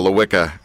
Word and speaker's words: Lelwica: It's actually spelled with L-E-L-W-I-C-A, but you don't have to pronounce the second L, and that makes it just a lot easier. – Thank you. Drop the second L, Lelwica.